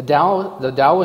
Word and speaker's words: Tao: And I picked out the chapter on Taoism today, which is spelled T-A-O, but it's pronounced Tao, as if it were a D.